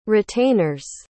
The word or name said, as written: retainers